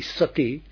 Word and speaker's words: sati: but if you use the Pali sati, it's a very short two-syllabled word.